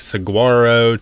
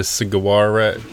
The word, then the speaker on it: saguaro: I don't know why we didn't just like look it up and listen to someone pronounce it correctly at first, but yeah, we went from like saguaro to saguara to I don't know. – Yeah, but it is saguaro. – Yeah, you don't pronounce that G.